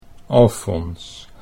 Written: Alfons

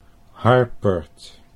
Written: Harpert

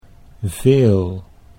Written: veel

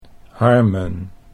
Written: Harmen

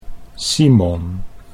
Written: Simon

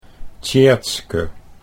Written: Tjeerdske

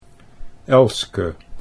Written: Elske

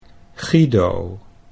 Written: Guido

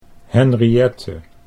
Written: Henriette

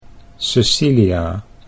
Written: Cecilia

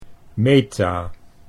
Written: Meta

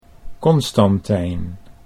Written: Constantijn

